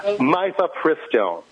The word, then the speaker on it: Mifepristone: The name of the book is From Malthus to Mifepristone, which I now mispronounced. Mifepristone, which is an abortifacient pharmaceutical.